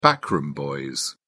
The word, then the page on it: backroom boys: British English